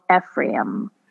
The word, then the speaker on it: Ephraim: So he has retreated with his disciples to a region near the wilderness at a village called Ephraim. Did I say that right? Where he stayed with his disciples. – Ephraim.